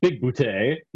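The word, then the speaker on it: Bigboote: John Big Boutte, you have to say it right.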